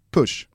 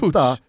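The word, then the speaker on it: Puig: Så här står det, Catalan pronunciation, Ricard Push. Ruskigt mäta Push. Hur dåligt du uttalar ordet pronunciation. – Pronunciation. – Ja. Det var metakul, ja, men, att du inte kunde uttala just det ordet. – Ja, men här är faktiskt Wikipedia bra. Då har de skrivit då, katalanskt pronunciering. – Pronunciation. – Pronunciation är Ricard Push.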